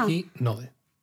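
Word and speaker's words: Hatsuhinode (初日の出): Eh, dentro del Hatsumode está muy relacionado otro primero que es el Hatsunohide. – ¿Lo digo bien? – Siempre lo digo al revés, ¿verdad? – Siempre lo digo al revés. – Me pasa. Hatsuhinode.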